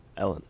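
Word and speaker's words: Elin: Wait, how did you pronounce his wife? – Alin. – Alin? – Is it not? – Is that like Greta? – Alin. I mean, it's Swedish. – Alin. – It's E-L-I-N. – Alin. – Ellen.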